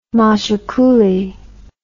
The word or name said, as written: machicoulis